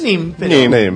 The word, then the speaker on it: Nimes: No sabía cómo se pronunciaba. ¿Nimes? – Nimes. – Murió en Nimes.